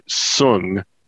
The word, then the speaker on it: Soong: Okay, it's Dr. Noonien Sung. And it's spelled S-O-O-N-G.